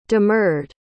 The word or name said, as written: demurred